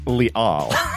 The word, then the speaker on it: Liel: And Senior Writer Liel Leibowitz. – It's pronounced Leal.